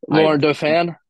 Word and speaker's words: Laurent Dauphin: Laurent Dauphin. – Laurent Dauphin.